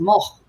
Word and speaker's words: Moog: What he explains in there is it's a Dutch name, and in Dutch it's pronounced Moog.